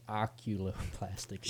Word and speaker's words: oculoplastic: What is oculoplastic? – Sure.